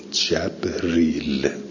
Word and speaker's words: جبریل: قرأ ابن کثیر، ابن کثیر خونده من کان عدوا لجبریل ریل به فتح الجیم و کسر را من غیر همزه جبریل.